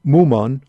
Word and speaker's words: Mumon: And as I said, this at this monastery there was halls connected to Wumen or Mumon. Again, I'm pronouncing it both in the Chinese way and the Japanese way of pronouncing his name, who lived there and who put together a collection of 48 koans.